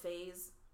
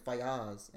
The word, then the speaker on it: Faiyaz: Is that how you're pronouncing his last name? – It's Faiyaz. I'm pretty sure. Yeah. – I've been pronouncing it Faiz. – No, I'm pretty sure it's Faiyaz.